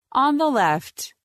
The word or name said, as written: On the left